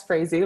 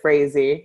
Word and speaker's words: Frazee: So like Angela said, my name is Margot Frazee. Yeah, it's Frazee, like crazy. It's the French word for strawberry, but we pronounce it Frazee.